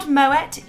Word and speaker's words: Moët: Claude Moët, yes, I am saying it right, we'll explain why in a moment, founded the House Moët and Chandon in 1743.